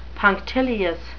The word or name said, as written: punctilious